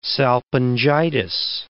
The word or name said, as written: salpingitis